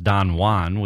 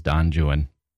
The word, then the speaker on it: Don Juan: So, yes, it's pronounced Don Juan. We all know the Spanish, including Jonathan Keeble, the narrator. Poor narrator, taking some shots on Audible. Here's the thing. Byron's Don Juan actually is Don Juan. That's just how it is.